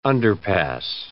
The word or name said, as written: underpass